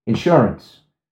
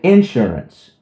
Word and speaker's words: insurance: insurance. She will say insurance, and the accent is on the end.